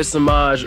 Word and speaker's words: Samaj: It's your boy, Samaj, rhymes with Minaj.